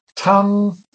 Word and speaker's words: tongue: Tongue is pronounced tongue, and the mnemonic could be your mother tongue, your lingua materna is a mother tongue.